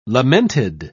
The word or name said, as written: lamented